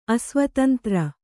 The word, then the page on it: ಅಸ್ವತಂತ್ರ: asvatantra